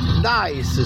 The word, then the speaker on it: Dice: Ma Dice si scrive D-A-I-S? – No. – Che non lo capisco, chiedo per un amico. – No, perdona, D-I-C-E.